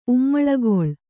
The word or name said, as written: ಉಮ್ಮಳಗೊಳ್